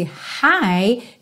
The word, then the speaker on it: high: Lembrando, esse high tem a mesma pronúncia do hi, hello, how are you doing? Ok? Hi. Então, esse GH, você não precisa se preocupar com ele.